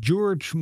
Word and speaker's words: Gyorgy: Het is G-Y-O-R-G-Y. -G y wat natuurlijk een eh, Hongaarse achtergrond suggereert. En daarmee ook meteen eh, eh, de, het besef dat die laatste Y natuurlijk nooit wordt uitgesproken. dus het is inderdaad Katie George moet het worden uitgesproken.